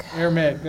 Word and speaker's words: Aramaic: The Old Testament was written in Hebrew and Aramaic. I can't pronounce it. – Aramaic, there you go.